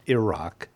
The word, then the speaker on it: Iraq: All the way to the general officers who've been through every training school in the world, Iraq is pronounced Iraq by Iraqians. – Yeah. – Or Iraqis. – My mouth could never. It's always Iraq. – It's like, just think of go-go. Ear. Rock.